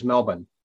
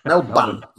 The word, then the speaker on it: Melbourne: We went to Auckland, then on over to Sydney and learned how to pronounce Melbourne properly as Melbourne, right? – Yes. Oh, good, good. Yeah. When you said I learned how to pronounce Melbourne properly, I thought, that's not it. Melbourne. Very classy.